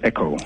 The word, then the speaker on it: Ekow: It's pronounced EKOW.